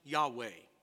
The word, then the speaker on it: Yahweh: It's pronounced Yahweh.